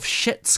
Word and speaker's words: Schitt's: Schitt's Creek, and I think I'm allowed to say that word in this context. – yeah, because you, you you pronounce that you put the C in there.